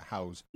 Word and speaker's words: Houzz: I don't know if I pronounced that right. House. – H-O-U-Z-Z. – Z-Z, yeah, House.